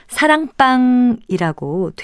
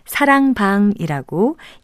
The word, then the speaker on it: 사랑방: 사랑방이라고 된소리로 발음하는 분들도 있는 것 같은데요. 글자 그대로 사랑방이라고 예사소리로 발음하는 것이 맞습니다.